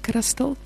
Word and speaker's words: Christel: So u kan my kry op Facebook, Christel Jacobs. Ek het 'n baie unieke naam. You spell it Christ all. So dit is my naam, Christel Jacobs op Facebook.